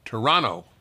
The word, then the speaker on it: Toronto: Or as you say in Toronto, Toronto. They don't pronounce the second T.